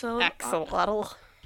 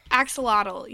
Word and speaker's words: Axolotl: How do you say that? – Axolotl. – Axolotl, yeah.